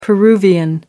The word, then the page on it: peruvian: American English